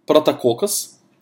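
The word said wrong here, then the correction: «прототокос» (πρωτότοκος): «прототокос», прошу прощения, «прототокос».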